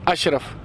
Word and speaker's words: Ashraf: My name is Ashraf. – How do you say it all? – A-S-H-R-A-F, Ashraf.